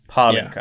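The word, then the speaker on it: Pálinka: So it's like the emphasis is on the A. Palinka.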